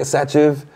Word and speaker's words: Sachiv: I'm like, yeah, Sachiv.